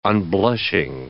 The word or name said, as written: unblushing